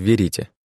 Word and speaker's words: Верите: Резиденция клана неназываемых находилась в Верите, с ударением на втором слоге.